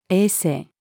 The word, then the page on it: 衛生: female